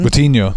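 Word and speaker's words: Moutinho: How do you even say his name? – Motino.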